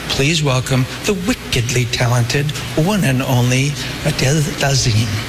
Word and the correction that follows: Idina Menzel: You pronounce it Idina Menzel.